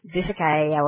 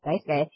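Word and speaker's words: Daisuke: His, you know, Dusakai, whatever. I mean, it's so easy to pronounce if you get it. It's Dice Guy.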